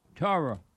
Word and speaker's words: Torah: It's a Jewish Bible called Torah. – Yep. – Torah.